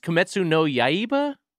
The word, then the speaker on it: Kimetsu no Yaiba: Kimetsu no Yuiba, because it's like the fucking. – Yeah, that three times fast. – I think it's Kimetsu no Yaiba. I may have said it wrong.